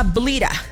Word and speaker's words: Bleta: Lo que pasa es que se llama Blita. Como Bleta, así se escribe su nombre.